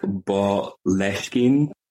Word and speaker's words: Boleskine: However, there have been many ways I have seen Boleskine pronounced. Some people say Boleskine, some people say Boleskine, but me and the Gaelic Umphies have discussed, and we have decided that it would be pronounced like from the Gaelic Boleskine, which is like the area name. So Boleskine is like the closest I can get to that.